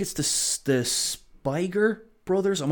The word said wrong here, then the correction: Spierig: Michael Spierig and Peter Spierig.